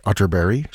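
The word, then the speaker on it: Atterberg: It's spelled Otterberg, but pronounced Otterberry, just in case you were Googling. And this is entitled Adagio Amoroso, played here by the Camerata Nordica, conducted by Ulf Wallin.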